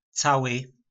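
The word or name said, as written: Cały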